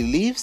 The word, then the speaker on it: leaves: And number 7, if he leaves his parents, his life will be very hard. So that's the third person singular of the verb to leave, long E sound.